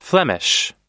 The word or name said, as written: flemish